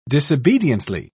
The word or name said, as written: disobediently